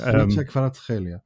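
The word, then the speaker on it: Khvicha Kvaratskhelia: No, it's Hlicha Kvaratskhelia. He said it himself. They because there were so many questions, he did a video. Napoli put out a video with him pronouncing his name, and I listened.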